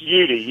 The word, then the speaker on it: Udy: It's Udy. U-D-Y. Just U-D. All you got to say is Udy.